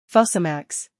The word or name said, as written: Fosamax